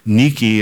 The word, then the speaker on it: Nike: It's actually pronounced Nike in the Greek.